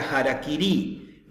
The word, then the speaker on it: harakiri: Por eso los shintoístas, los japoneses que practican la religión shinto, cuando se suicidan ritualmente se hacen el sapuku o harakiri, que se pronuncia harakiri,